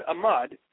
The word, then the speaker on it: amad: The word amad is made up of two syllables, of which the first is am, the Hebrew word for people.